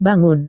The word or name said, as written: bangun